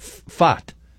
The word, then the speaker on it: Pfaadt: And somebody named P-F-A-A-D-T is pitching for Arizona. – Pronounce that. – And we've decided that that's fat. Fat. – Pronounce it. – Fat. – Okay. – Like font, except no N. – Not font. – Forget about the P, forget as Shaggy said. So you don't pronounce the P and you don't pronounce the D.